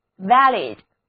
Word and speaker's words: valid: 字母 A 要读作梅花音，而字母 I 要读作短的 it， valid。